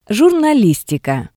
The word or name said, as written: jurnalismika